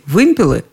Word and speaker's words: вымпелы: Хотите – вымпелы, хотите – вымпела. В таких случаях я, как правило, обращалась за советом к самому строгому арбитру, к словарю ударений Агеенко и Зарвы. Там действительно присутствовал всего один вариант с ударением на первом слоге «вымпелы», «вымпелов», «вымпелами».